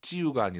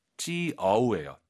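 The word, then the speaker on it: 就: 찌우가 아니라 아이유 사이에 오 넣어서 찌우. – 찌어우예요. 찌어우. 그렇죠.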